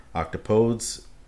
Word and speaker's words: octopodes: Octopodes, it should be noted, takes a slightly different pronunciation than the other two words, placing the emphasis on the second syllable rather than the first.